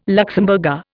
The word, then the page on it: Luxemburger: Indian